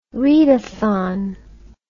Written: readathon

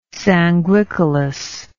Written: sanguicolous

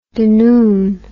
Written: dunoon

